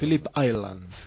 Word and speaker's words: Phillip Island: Si lo digo bien o no lo digo mal, no, vosotros... – Phillip Island. – Muy bien.